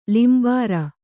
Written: ಲಿಂಬಾರ

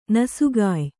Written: ನಸುಗಾಯ್

